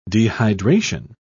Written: dehydration

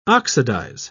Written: oxidize

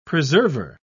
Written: preserver

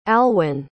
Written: alwyn

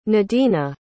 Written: nadina

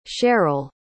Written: sharyl

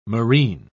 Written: marine

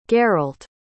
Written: geralt

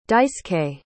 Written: daisuke

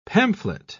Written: pamphlet

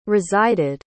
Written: resided

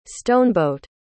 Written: stoneboat

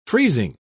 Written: freezing

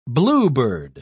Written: bluebird

